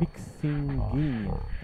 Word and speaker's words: Pixinguinha: Hablan de influencias de Nina Simone y Solguinha. Es Pixinguinha, ahí lo dije bien, Pixinguinha, que no conozco quién es.